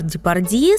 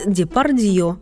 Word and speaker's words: Депардье: Если вернуть законные права опальной букве, то Жерар Депардье станет Депардье, а Мирей Матье выйдет на российскую сцену как Матье.